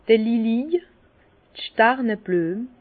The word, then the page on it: d'r Lilij, Stàrneblüem: Bas Rhin; Herrlisheim